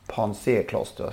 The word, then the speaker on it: Pansé Kloster: Det, det vann ju, på tal om i lördag så vann ju då Pansé kloster, ska det uttalas så? – Ja.